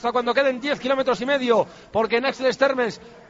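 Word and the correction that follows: Ax-les-Thermes: Bueno, es una pronunciación mala, pero ya me comprenderá. Axel Terme, ¿no, Quique? – Sí, perfectamente.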